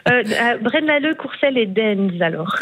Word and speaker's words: Deinze: Et il y en a un, je m'excuse pour la prononciation, c'est Dinze. Ça s'écrit D-E-I-N-Z-E. Denz, pardon. – C'est en Flandre, ça, ou c'est peut-être une commune à facilité. Denz, oui, c'est ça.